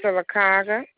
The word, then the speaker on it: Sylacauga: Highway 280 in, I'm not sure how to pronounce this, Sylacauga? – Sylacauga, mm-hmm.